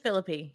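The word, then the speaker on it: Phillippe: Is it Philippe? – Philippe? – It's just Philippe. Yeah. – Yeah, it's Philippe.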